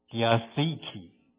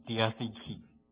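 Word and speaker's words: Diatheke (διαθήκη): 那在新约圣经中的这个约呢，希腊文的这个约呢，怎么念 Diatheke，看那个重音长那个 Diatheke，至少出现了 33次。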